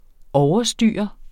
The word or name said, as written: OVERSTYREDE